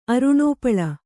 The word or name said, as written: ಅರುಣೋಪಳ